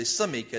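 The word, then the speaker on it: semikha: But those few were considered to have something called shmika, if I'm saying it correctly, or samika. It's actually samika. It's a samik. It's not a sheen. It's samika.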